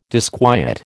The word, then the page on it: disquiet: American English